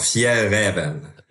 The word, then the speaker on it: Fjällräven: Genau, Fjällräven.